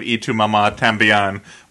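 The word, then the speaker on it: Y tu mamá también: It was like the ending of Y Tu Mama Tambien, when once the...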